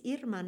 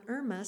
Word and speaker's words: Irma: It's just that in my case, it's Irma, not Irma, so I would appreciate people calling me that.